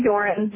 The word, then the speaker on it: Doron: Your name, Doron, is that how it's pronounced? – That's one of the ways. – How do you say it? – I say Doron. – Doron. – Like Doron.